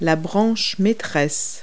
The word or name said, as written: limb